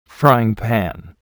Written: frying pan

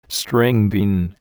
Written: string bean

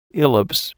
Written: ellipse

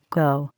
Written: go

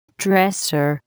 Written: dresser

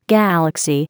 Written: galaxy

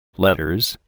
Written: letters

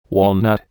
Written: walnut

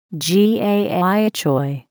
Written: gai choy

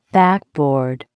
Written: backboard

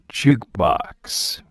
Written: jukebox

